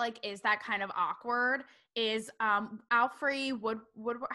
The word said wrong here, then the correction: Alfre Woodard: How do you pronounce her name? She was in Alfre Woodard. Yeah.